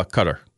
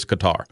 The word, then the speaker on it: Qatar: Well, if you listen to the way the Arabs say it, it's Qatar. If you listen to the way we're supposed to pronounce it, it's Qatar. So I just looked it up. and both ways are used.